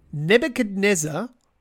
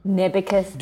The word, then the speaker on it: Nebuchadnezzar: Nebuchadnezzar was the ruler of which ancient empire? – One more time. The name? – Nebuchadnezzar. – Nebuchadnezzar.